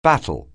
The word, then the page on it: battle: anglais